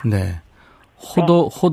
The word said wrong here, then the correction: 효도: 호도가 아니라 효도죠? 아, 네, 효도.